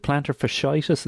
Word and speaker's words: plantar fasciitis: A little bit of plantar, is it plantar fasciitis? Is that how you call it? Is that how you pronounce it? – Yes, that's it.